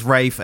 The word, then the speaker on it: Ralph: Well, he says Rafe, and it's like, mate, your name is Ralph. All right? – Does he pronounce it Rafe? – Everyone calls him Ralph Fiennes, and it is literally spelled Ralph.